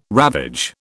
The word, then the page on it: ravage: British English